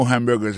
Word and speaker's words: hamburger: On a remarqué que dans le mot hamburger, ça a comme d'habitude, il y a trois syllabes là-dedans, quand on appuie et puis plus ça, c'est le premier syllabe là.